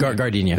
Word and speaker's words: Gardenia: Rick Moranis, Ellen Green, and Vincent Gardenia? Is that how you pronounce it? – Gardenia.